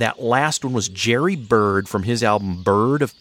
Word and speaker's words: Byrd: The album is spelled after his name, B-Y-R-D of Paradise. But the song title is Bird, B-I-R-D of Paradise.